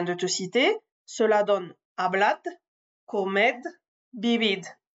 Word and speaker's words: hablad: Selon les régions, ce D final sera prononcé comme un D, comme je viens de te le prononcer, ne sera pas prononcé du tout, ou sera prononcé comme un Z un peu atténué, hablad, comed, Vivid.